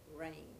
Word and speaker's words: Range: Yes, my name is Athalie, that is spelled A-T-H-A-L-I-E, the last name, Range, R-A-N-G-E.